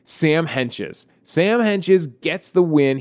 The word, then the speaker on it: Hentges: Here's the thing about his name. You see it, and you see that T in the middle of his name. You blow straight through that T. That's how you say his name, Sam Henches.